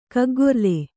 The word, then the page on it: ಕಗ್ಗೊರ್ಲೆ: kaggorle